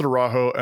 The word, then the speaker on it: Araújo: They pronounce it Araújo, and it makes me mad because I learned that Araújo, but that's probably the Portuguese pronunciation.